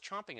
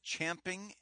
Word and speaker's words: champing: It is champing at the bit. – Are you saying champing, or is that just how you pronounce chomp? – No, champing with an A.